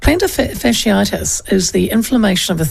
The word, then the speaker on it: plantar fasciitis: Oh dear, it looks like I might have plantar fasciitis. Have I said it right? – Plantar fasciitis.